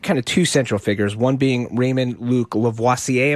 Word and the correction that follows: Levasseur: Am I saying that right? – He's from Maine. He just says Levasseur. – Levasseur.